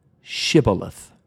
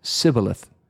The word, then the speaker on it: shibboleth: When the Ephraimites tried to escape for their lives across the Jordan River, they had to pronounce the word shibboleth. But the Ephraimites had a little speech therapy issue. They could not pronounce it right. They said Sibboleth.